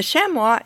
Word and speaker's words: chamois: The chamois is a species of what are called goat antelopes. Its name is French, and it's spelled C-H-A-M-O-I-S, but in English, a lot of people pronounce it chamois.